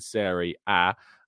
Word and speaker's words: Serie A: But it is Mikel Arteta and Serie A.